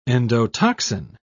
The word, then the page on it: endotoxin: British English